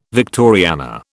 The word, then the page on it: Victoriana: British English